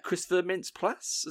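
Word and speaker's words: Christopher Mintz-Plasse: Yeah, Christopher Mintz Plasse.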